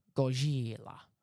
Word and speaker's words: Gojira: Yeah, you've got to love Godzilla, or as it's appropriately pronounced. – Gojira. – What? That's not how we say it. – Well, it's actually how the Japanese say it.